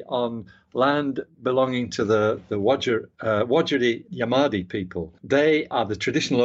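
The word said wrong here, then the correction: Wajarri Yamaji: I did mispronounce that, actually, because there's when you see it written, what you pronounce is slightly different. It is Wajiri Yamaji. That's the correct pronunciation.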